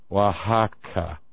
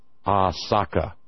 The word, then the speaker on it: Oaxaca: And the real name of the place was Huaxacac. The Spanish invaders and murderers couldn't pronounce the native words. So Huaxacac, because they couldn't pronounce the sh diphthong, became Oaxaca. And they just dropped the last k sound at the end.